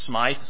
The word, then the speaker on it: Smyth: The 19th century Presbyterian minister Thomas Smith, some pronounce it Smythe, spelled S-M-Y-T-H,